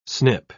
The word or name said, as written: snip